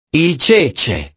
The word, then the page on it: il cece: italiano